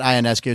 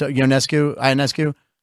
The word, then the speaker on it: Ionescu: And the thing is the one thing I wanted to see was Steph Curry go against, it's not Ionescu, it's Ionescu. I heard it pronounced differently. – I know it as Ionescu.